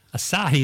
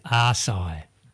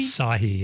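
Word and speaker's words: açaí: Anyway, they found Asahi is the most mispronounced word. A-C-A-I. You know, you'll have arigato gozaimasu. – That's it. – Konnichiwa, brother. – A lot of people say Asai. – Oh, don't. Really? – Asahi, yeah.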